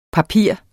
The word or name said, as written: papir